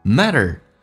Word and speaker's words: matter: Matter, 在美式发音当中，两个元音中间所加的辅音要被浊化 ,T 说成 D, 叫 matter。